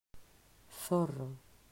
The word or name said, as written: Zorro